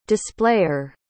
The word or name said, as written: displayer